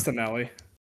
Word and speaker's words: Tonali: Yeah, it's Tonali.